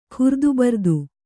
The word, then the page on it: ಖುರ್ದುಬರ್ದು: khurdubardu